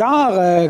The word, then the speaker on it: arrhes: Voilà, pas les arts A-R-T, mais les arts, comment ça s'écrit ça? A-R-R-H-E-S, eh oui, c'est un mot qu'on utilise trop peu souvent.